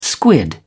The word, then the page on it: squid: British English